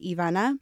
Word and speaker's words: Ivana: but we pronounce it in Spanish and that's how it's pronounced, Ivana.